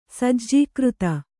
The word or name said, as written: ಸಜ್ಜೀಕೃತ